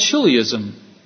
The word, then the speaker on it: chiliasm: When you look at the word, it has chili in it, but it has nothing to do with chili. We believe in a concept called chilliism. The word is spelled C-H-I-L-I-A-S-M.